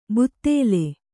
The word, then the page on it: ಬುತ್ತೇಲೆ: buttēle